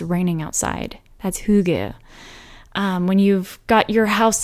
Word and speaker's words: hygge: But it's the concept that I mistakenly thought was pronounced Hygge, but it's actually pronounced Hygge.